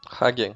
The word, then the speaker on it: Hagen: Esse rei ele tinha uma irmã chamada Gutrune e um meio-irmão chamado Hagen. – Hagen, para saber como é que fala é só lembrar do sorvete. hagen das.